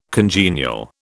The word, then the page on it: congenial: British English